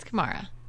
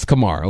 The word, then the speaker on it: Kamara: Is it Kamara or Kamara, Dooley? – I think it's Kamara. – It's Kamara, okay, because I've heard it. It goes back and forth. All right. Alvin Kamara is one of the most underrated backs in the league.